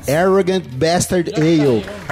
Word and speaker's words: Arrogant Bastard Ale: Faz a pronúncia correta agora. – Arrogant Bastard Ale. – Olha só, agora com uma pronúncia melhor que a minha.